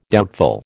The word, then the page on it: doubtful: American English